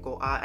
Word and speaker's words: Guo: I always pronounce her name Goa. I know it's like Go, like G-U-O. But I listen to like how they pronounce it within the show and I'm trying to replicate that.